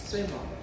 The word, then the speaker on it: Сайбаба: Мы с вами говорили о том, Сайбаба. Или Сайбаба. Не знаю, как правильно ударение делать. – Сайбаба.